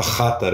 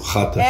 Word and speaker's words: Xatar: Eu já ia falar portuguesado aqui, o rapper Chatar, adorei que você já mandou a é. pronúncia certa. – Chatar, é isso? Chatar.